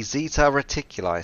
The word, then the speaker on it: Zeta Reticuli: It is the Zeta Reticuli system.